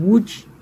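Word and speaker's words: Łódź: Não, ano que vem, em abril, vai ser o 4º concerto de Vila Lobos, com orquestra em Wood, se escreve Lodge, que é onde o Arthur Rubinstein nasceu.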